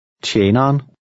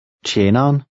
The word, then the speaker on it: tjeneren: Now repeat number 8 after the speaker, noting the sound of the initial TJ and the contraction of the last two syllables. – Tjeneren. Tjeneren. – This contraction occurs in everyday speech, and the ER is silent.